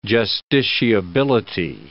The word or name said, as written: justiciability